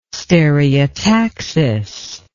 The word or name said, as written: stereotaxis